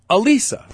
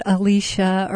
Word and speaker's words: Elisa: Like if you can say Mona Lisa, you just leave the moan off and it's Elisa, right? – Not Alicia or Alyssa.